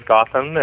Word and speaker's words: Gotham: I did change the spelling, although never officially, to G-O-T-H-A-M, just because I used to work in television a lot and nobody could ever pronounce my name, and I've always pronounced it as Gautam,